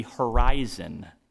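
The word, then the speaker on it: horizō: The root of that word, predestinate, refers to the horizon. It's actually the Greek, the way you pronounce it is horizo. Horizo, it's horizon.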